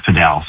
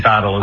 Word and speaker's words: padel: And in the Spanish language, it's pronounced padel. So I guess that's the way it should be pronounced, but a lot of people say paddle as well. So either one works.